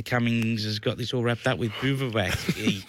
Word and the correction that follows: Bivouac: How do you pronounce it? – I'd say Bivouac. – Bivouac. – Yeah, I'd go the Bivouac.